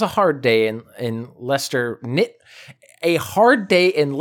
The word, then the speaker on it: Leicester: Leicester. It's Leicester. – Of course, it's like a Worcester situation.